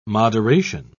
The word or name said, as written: moderation